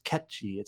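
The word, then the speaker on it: Cait Sith: That character won't show up in the remake, but in like remake part two, like that's how you pronounce it, even though he was in the movies and whatnot. It's Ketchi. It's like Irish or something.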